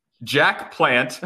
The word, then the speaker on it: Jacques Plante: In the words of former NHL goaltender Jacques Plante, pronunciation check? – Sure. Sure. – Oh, Jack Plant.